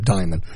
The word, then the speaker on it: Dimon: Mary Erotis, a top lieutenant to chief executive Jimmy Dimon, D-I-M-O-N. I always forget how to pronounce these things. – Diamond.